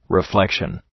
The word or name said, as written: reflexion